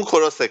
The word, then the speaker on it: کروس: کروسه کاره. حالا تلفظ های مختلف داره تو فارسی، حالا شما کروس تلفظ کنید.